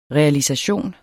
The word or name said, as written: realisation